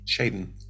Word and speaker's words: Cheydin: Jaden. – Cheydin. C H E Y D I N.